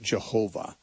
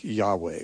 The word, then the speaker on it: Yahweh: and pronounce it Jehovah with a J sound at the beginning. More commonly, you know, Hebrew scholars would, I think, endorse a pronunciation more like Yahweh, which is, you hear that much more often now by those who are trying to be authentic.